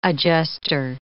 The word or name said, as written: adjuster